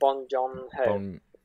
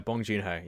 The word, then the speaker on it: Bong Joon-ho: Bong Joon-ho. – I think that is. Let me just check the Parasite. I want to make sure we get this. Yeah, Bong Joon-ho.